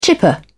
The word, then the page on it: chipper: British English